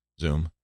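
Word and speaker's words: Xoom: How do you pronounce that? Zoom. Why didn't you use a Z? Well, because it's an X. But it looks like it's Zoom. No, it's just Zoom.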